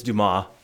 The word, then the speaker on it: Dumas: It's Dumas, yeah. – Dumas? – Yeah. With a silent S.